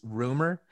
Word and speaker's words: RMR: then my number one pick, somebody that I think a lot of people should pay more attention to is Rumor, but it's spelled RMR. And he says he doesn't care if people call him RMR, but he does prefer to be called Rumor.